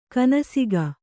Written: ಕೊನೆಸಾಗು

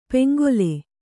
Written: ಪೆಂಗೊಲೆ

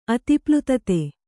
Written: ಅತಿಪ್ಲುತತೆ

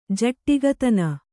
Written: ಜಟ್ಟಿಗತನ

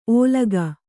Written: ಓಲಗ

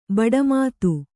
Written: ಭಾಟ್ಟಮತ

